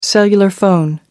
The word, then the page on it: cellular phone: British English